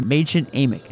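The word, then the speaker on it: Mädchen Amick: She's played by Machen Amick, is how you pronounce her name.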